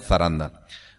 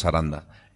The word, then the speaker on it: zaranda: Y hay una palabra que me gusta, por la sonoridad, por lo que expresa, que se llama zaranda, se escribe con Z, ¿no? Se pronunciaría en castellano zaranda. Nosotros decimos zaranda.